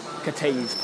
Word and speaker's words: Cathays: which is at Cattays Park, but it's spelled C-A-T-H-A-Y-S. As in cafes, and nobody would understand us.